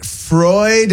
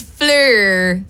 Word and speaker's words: PHLUR: Are you sure it's not Freud? It's not pronounced Freud? – No, it's Fleur.